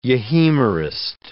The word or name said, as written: euhemerist